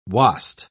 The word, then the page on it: wast: British English